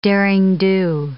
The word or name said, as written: derring-do